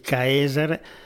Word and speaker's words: Caesar: Cesar, la E si, no, si, si pronunciava AE, quindi Caesar, da cui poi sono de- derivati Kaiser, Kaiser, Zar e così via, a seconda poi del...